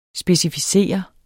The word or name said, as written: specificere